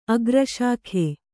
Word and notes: ಆಕರ್ಷಕ: ākarṣaka

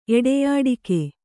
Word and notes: ಎಡೆಯಾಡಿಕೆ: kannada

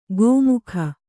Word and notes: ಗೋಮುಖ: gō mukha

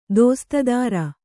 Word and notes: ದೋಸ್ತುದಾರ: Kannada

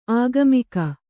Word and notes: ಆಗಾಮಿಕ: āgāmika

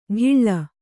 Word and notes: ಡಿಳ್ಳ: Kannada; ḍiḷḷa

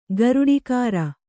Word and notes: ಗರುಡಿಕಾರ: garuḍikāra

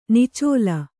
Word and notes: ನಿಚ್ಚಲು: niccalu; Kannada

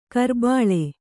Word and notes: ಕುರಿಬಳ್ಳಿ: kurin baḷḷi

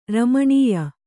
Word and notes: ರಮಣ್ಯ: ramaṇya

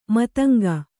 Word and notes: ಮತಂಗಿ: Kannada

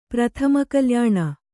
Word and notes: ಪ್ರಥಮಕಲ್ಯಾಣ: prathama kalyāṇa

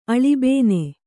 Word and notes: ಅಳುಬೇನೆ: aḷubēne

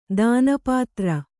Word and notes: ದಾನಪತ್ರ: dāna patra

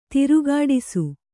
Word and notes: ದೊರೆಗೆಡಿಸು: doregeḍisu; Kannada

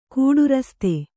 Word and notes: ಕೂಡುರಸ್ತೆ: kannada

